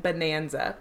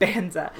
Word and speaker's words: Banza: Okay, but I always say it like Bonanza because I think it sounds like banana or it spells like banana, but it's not. There's one less N, so it's like Banza.